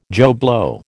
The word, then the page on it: Joe Blow: American English